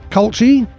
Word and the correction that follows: Colucci: Our pronunciation police there, Colucci.